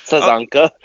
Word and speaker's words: Sazanka: Sazanka.